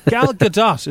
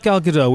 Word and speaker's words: Gal Gadot: Gal Gadot is her name. Not Gal Gadot. We've all been saying it wrong.